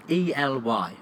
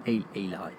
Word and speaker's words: Ely: E L Y. How do you pronounce that? – Ely. – Eli. – Yeah.